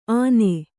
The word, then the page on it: ಆನೆ: āne